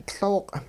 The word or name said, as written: Looq'